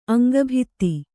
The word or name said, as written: ಅಂಗಭಿತ್ತಿ